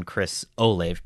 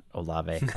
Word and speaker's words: Olave: Yeah, let me go back and correct my pronunciation on Chris Olave, by the way, not Olave. – I uh, uh, my hooked on phonics uh, skills aren't aren't exactly silent E. What is this?